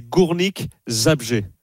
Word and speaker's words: Górnik Zabrze: Je l'ai travaillé, c'est Górnik Zabrze.